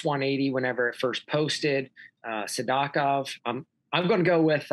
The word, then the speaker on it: Sidakov: He says Sadakov. I'm going to say Sadakov. I said Sidakov forever. Mm-hmm. I'm going to follow Belaglotsov there. I'll say Sadakov. So I knew Sadakov was going to move up.